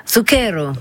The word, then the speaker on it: Zucchero: Comment vous prononcez déjà, vous ? – Zucchero. – Zucchero, ah oui.